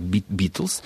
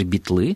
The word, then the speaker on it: Битлз: По поводу Битлз меня поразило, но у нас есть словарь трудности произношения и ударения Кирилла Сергеевича Горбачевича, да, и он дает это слово просто вот Битлз, и в речи молодежи Битлы, и, видимо, это, безусловно, подразумевается участники группы Битлз.